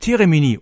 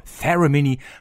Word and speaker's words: Theramini: Der Moog Teremini oder englisch ausgesprochen der Moog Theramini kommt in der klassischen nüchternen Moog-Verpackung, also Umzugskarton braun mit schwarzem Aufdruck.